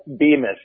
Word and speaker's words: Bemis: No, Mac, it's Phil Bemis, long E, not like the toilet seat that everybody sees.